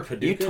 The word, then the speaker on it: Paducah: You ever been to Paducah, Kentucky? – How shit do you gotta be from Paducah but not Kentucky? – Paducah, Wyoming? – Oh, God. – But they pronounce it Patica out there.